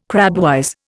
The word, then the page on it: crabwise: Indian